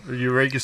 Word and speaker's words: Eureka: So, obviously, 171 years since the, how do you pronounce it? – Eureka Stockade.